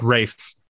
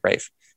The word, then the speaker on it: Rafe: You can kind of see that they are invested, especially the main guy, Rafe Judkins. I'm not sure how to say his name. – I think Rafe. – I think you're right, Rafe.